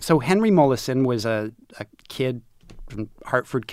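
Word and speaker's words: Molaison: Mollison.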